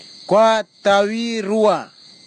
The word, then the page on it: kwatavirua: Kwamera